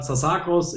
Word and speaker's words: Tsasakos: Heute mit einem Special Guest, dem Alexander Zasakos. Ich hoffe, ich habe es richtig ausgesprochen. Hallo, Alexander. Hallo. Ja, hast du richtig gemacht.